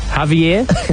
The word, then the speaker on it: Javier: La Liga president Javier, I want to go with, because it's Spanish, Kyle. So the J-A-V is pronounced Hav, isn't it?